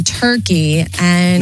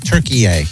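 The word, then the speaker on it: Türkiye: Turkey-A. That's how we now have to pronounce it. I've learned that in the World Cup. – What do you mean? – Not Turkey. It's Turkey-A.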